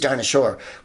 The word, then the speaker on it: Dinah Shore: And I know you're like, who's Dinah Shore? Well, her name rhymes with dinosaur, so she disappeared when the comet hit the Earth.